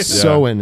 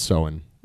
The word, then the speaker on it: Samhain: Soin, it would be. Samhain, it's pronounced Soin. – Oh, really? – Oh, really? – Yeah, it's an Irish word.